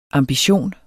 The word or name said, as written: AMBITIONER